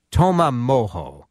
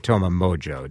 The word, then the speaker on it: Toma Mojo: I said it right. – Yeah, you said it right. – It's spelled T-O-M-A and then M-O-J-O. And Paul Backer is here along with Chris Neal. Paul, people make it Toma Mojo, don't they? – Yeah, they like to say Mojo.